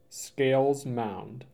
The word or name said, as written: Scales Mound